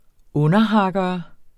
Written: underhakkere

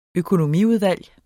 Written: økonomiudvalg

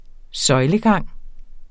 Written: søjlegang

